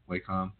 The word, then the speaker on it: Wacom: So that would be, I said it right that time, right? Wacom. – Yep, to get old Wacom.